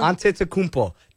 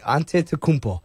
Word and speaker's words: Antetokounmpo: Antetokounmpo. That's how you say it. Antetokounmpo.